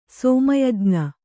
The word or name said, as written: ಸೋಮಯಜ್ಞ